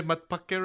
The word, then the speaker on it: matpakke: Do you still maintain these matpakke and metro fantasies? If not, what are your updated disappearance fantasies? Did I say it right? Did I say matpakke right? – You did.